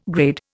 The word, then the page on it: grade: Indian